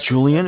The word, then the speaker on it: Julian: Okay, my name is Julian. It's J-U-L-I-A-N. – Okay. – I'm calling with Bank of Cardiff. Cardiff is spelled C-A-R, D as in David.